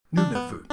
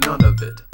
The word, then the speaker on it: Nunavut: And we've heard some people pronounce it none of it.